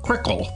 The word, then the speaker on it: Cyrkle: The song Red Rubber Ball, which went to number 2 in 1966, was sung by the band Crickle, that's spelled C-Y-R-K-L-E.